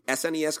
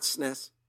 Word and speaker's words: SNES: So the other two pieces of information that they have provided is, number one, they have confirmed that the SNES Classic, which, by the way, that's the correct way to say it, not SNES, S-N-E-S, you monster.